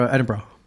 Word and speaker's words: Edinburgh: I was pronouncing it Edinburgh. – It's Edinburgh. – And I'm still not saying it right. Okay. Edinburgh. He's Edinburgh. – Like, drop the oro. Drop everything. Edinburgh.